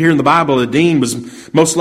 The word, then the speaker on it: Adino: That first name mentioned there, Adin, it's got an O on the end of it, but just pronounced Adin, but Adino, if you want to call that, was also known as Joshabim, or Jashabim.